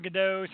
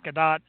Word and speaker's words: Gadot: I used to call her Gadot. Somebody told me it's Gadot.